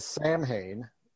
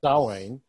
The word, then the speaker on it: Samhain: He says Samhain, which is not the way you pronounce it. It's Dawain.